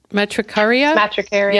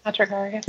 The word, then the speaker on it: Matricaria: Matricaria? – Matricaria. – Yeah. – Yep. Matricaria.